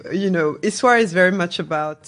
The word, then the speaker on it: Yswara: Am I correct in the pronunciation? – Well, yeah, Yswara.